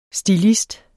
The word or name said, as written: stilist